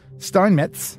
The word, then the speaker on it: Steinmetz: One German-born American mathematician and electrical engineer, Dr. Charles Steinmetz.